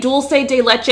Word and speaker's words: dulce de leche: That is how you say dulce de leche, is dulce de leche.